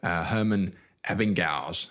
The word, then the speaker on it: Ebbinghaus: And this had to do with a chap that I'm just going to butter his name now, Herman Ebbinghaus. How would you say his name? – Exactly like that.